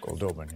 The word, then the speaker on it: Goldobin: Goldbeaded. I don't know how to pronounce that name pretty good. – Goldobin, yeah.